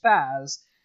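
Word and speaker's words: FAZZ: Not the full armor Double Zeta, the Faz, because this thing's name is pronounced as Faz.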